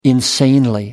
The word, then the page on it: insanely: American English